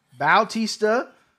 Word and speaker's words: Bautista: Bautista. I like how you pronounce the name because that's how you pronounce it.